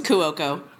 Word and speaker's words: Cuoco: I've never heard of Cuoco, though. – It's Cuoco.